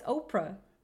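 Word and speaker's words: Oparah: How can you not pronounce that right? It's Oprah. – It's spelled Opera.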